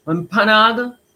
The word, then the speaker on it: Wampanoag: So it features a Wampanoag, correct me out there, my Indigenous people, if I'm saying it wrong. Wampanoag. Sounds about right. Wampanoag. It has that kick.